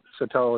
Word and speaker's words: Sartell: And this year it's between St. Cloud Icebreakers and Sartell Sauk Rapids. Did I pronounce that right? – Yep, Sartell and Sauk Rapids, yep.